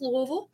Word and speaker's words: Louisville: It's pronounced Louisville, and it's pronounced Bellarmine.